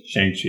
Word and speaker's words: Shang-Chi: How do you pronounce his name? Shang-Chi? – Shang-Chi, yeah.